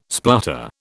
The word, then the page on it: splutter: British English